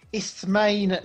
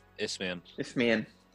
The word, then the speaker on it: Isthmian: Yeah, I was looking on the Sky Sports app, and just underneath the FA Vars is the Isthmian League North. – Isthmian.